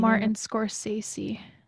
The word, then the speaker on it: Martin Scorsese: Martin Scorsese.